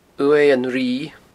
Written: uaimh an righ

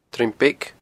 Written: Druim Beag

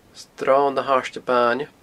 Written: sronnahairdebaine

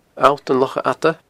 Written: Allt an Locha Fhada

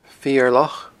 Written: Feur Loch

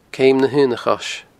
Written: Ceum na h-Aona Chois

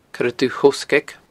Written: coiredubhthoghsgaig

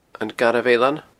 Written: Garbh Eilean

